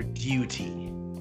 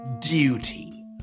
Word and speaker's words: duty: And, in college, my professor said you always have to pronounce it like you're like you're British. You have to pronounce it duty, because if you say duty, like us Americans like to say, it just sounds like what you do in the bathroom.